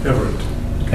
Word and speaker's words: Everett: Everett.